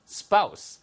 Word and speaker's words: spouse: Your current spouse. S-P-O-U-S-E는 발음이 두 가지예요. Spouse도 되고요. Spouse도 됩니다. 아시겠죠? Spouse 아니면 spouse. 다 가능하니까 알아두시고요.